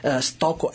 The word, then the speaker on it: Stocco: Stocco, S-T-O-C-C-O.